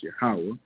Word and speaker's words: Yahweh: That's what Jehovah, they're saying, which actually pronounced Yahweh.